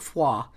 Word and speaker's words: froid: Because as a non-French speaker, I see OID and I don't think froid. I think oid, you know?